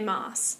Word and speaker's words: Maas: Maas. And yes, she does pronounce her name like that. – Okay. – But it looks like Mass.